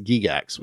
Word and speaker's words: Gygax: It's actually pronounced Gygax, we found.